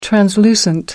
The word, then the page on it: translucent: American English